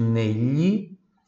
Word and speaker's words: negli: Veja, nele, GLI som de LHI, tá? GLI som de LHI, por isso nele.